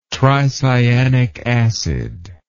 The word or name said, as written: tricyanic acid